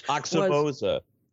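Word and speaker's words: Aoxomoxoa: And that was the Grateful Dead's China Cat Sunflower from that unpronounceable Axiomoxa whatever album, which Oxymoza. – Say it again. – Oxymoza. – Oxymoza. – How I was raised to pronounce it. I think that's right.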